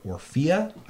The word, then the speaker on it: Orphea: But most notable among the game's BlizzCon reveals was that of a new character, Orphea.